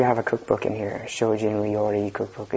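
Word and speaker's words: shōjin (精進): And the Japanese pronunciation of this is shōjin.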